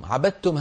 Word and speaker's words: عبدتم: عبدتم هذه أصلها عبدتم، دال ساكنة بعدها تاء، لكن الدال والتاء من مخرج واحد، والأول والأولى منهما وهي الدال ساكنة، فإذا التقتا لابد من إدغام الدال في التاء بحيث يصير النطق تاء مشددة.